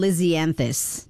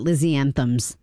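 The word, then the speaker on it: lisianthus: Stifler did pronounce those flowers incorrectly. It's Rock 101.1. They're double Lizzianthus, not Lizzianthems.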